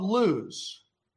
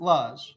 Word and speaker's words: Luz: And on his way to his uncle's house, he makes camp at a little place called Luz. Some pronounce it Luz. It doesn't really matter, but it's a nowhere place.